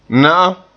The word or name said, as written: na'am